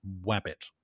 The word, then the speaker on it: Wabbit: And yes, that is pronounced with a W, guys. Wabbit.